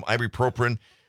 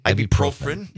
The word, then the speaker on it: ibuprofen: You just saw him in the car with your lovely wife Donna, who very nicely brought me down some ibuprofen. I cannot pronounce the word ibuprofen. – Ibuprofen.